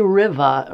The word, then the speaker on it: Rivah: Why do they say Rivah around here and not river? Do we know? – Most things are said differently than they're spelled around here. – Well, some people actually spell it R-I-V-A-H.